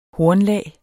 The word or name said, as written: hornlag